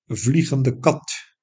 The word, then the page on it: vliegende kat: Dutch